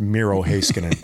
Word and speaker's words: Miro Heiskanen: It's pronounced Miro Heiskanen.